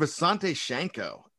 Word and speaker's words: Visanthe Shiancoe: Sidney Rice, Harvin, the tight end Visanthe Shiancoe, and Bernard Berrian, who's a speedster. Man, I pronounce Visanthe Shiancoe. I'm really proud of myself.